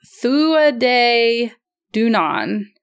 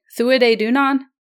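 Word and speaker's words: Tuatha Dé Danann: It says pronounced Thuaday Dunan. Thuaday Dunan.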